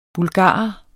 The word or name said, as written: bulgarer